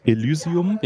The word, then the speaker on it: Elysium: Elysium? – Ja.